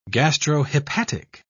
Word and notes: gastrohepatic: British English

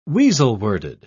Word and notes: weasel-worded: British English

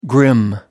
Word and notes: grim: American English

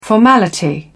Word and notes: formality: British English

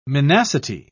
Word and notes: minacity: British English

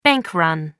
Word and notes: bank run: American English

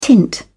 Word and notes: tint: British English

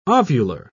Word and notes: ovular: British English

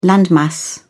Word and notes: landmass: British English

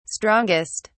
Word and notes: strongest: British English

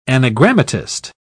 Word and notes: anagrammatist: British English

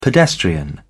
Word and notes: pedestrian: British English

